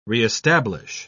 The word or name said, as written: re-establish